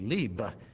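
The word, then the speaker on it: lib (לב): just and phonetically L-E-B with a long E, lib.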